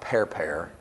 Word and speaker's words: Pharpar: Pharpar is actually pronounced Perper in the Hebrew.